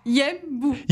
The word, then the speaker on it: Yembu: Mais pourtant c'est deux syllabes. – Dis-moi. – C'est... Yembu.